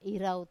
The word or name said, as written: irau